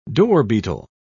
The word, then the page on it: dorbeetle: British English